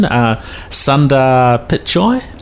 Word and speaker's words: Sundar Pichai: Yeah, so, and I'm not sure of the pronunciation, Sundar Pichai? – That's him. – Is that about roughly how you say it? – That'll do.